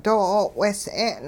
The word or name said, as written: doo 'o'wehs'e'n